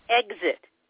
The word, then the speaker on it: Exit: It's Merle, M-E-R-L-E, and the last name is Exit, E-X-I-T.